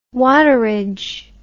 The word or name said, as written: waterage